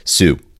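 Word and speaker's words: Sault: It's French, so it's Sue.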